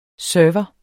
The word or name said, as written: server